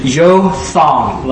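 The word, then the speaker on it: Jotham: And then one of the sons is going to escape, and his name is how you pronounce it, is Yothawn, like Yothawn.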